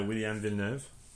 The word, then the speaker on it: William Villeneuve: I know a lot of Habs fans are going to want St. John's defenseman William Villeneuve. Like, I don't know how to say his name very well. – Yeah, you did pretty good. Yeah, William Villeneuve.